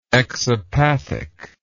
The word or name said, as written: exopathic